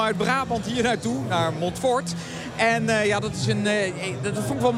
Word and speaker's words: Montfort: Ik werd daar terecht door gecorrigeerd in de NPO Radio 1. Het is Montfort. – Oh, klemtoontechnisch gesproken. – Montfort, ja. Ik heb Montfort. Wist u dat, meneer, dat het Montfort is en niet Montfort?